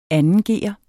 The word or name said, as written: 2